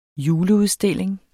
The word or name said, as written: juleudstilling